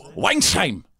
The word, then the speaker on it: Weinheim: Weinheim.